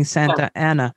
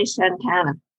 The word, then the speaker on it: Santa Ana: In general, you've been saying Santana. Is that right? I've been saying Santa Ana, but I guess it's Santana. – Well, the locals say Santana.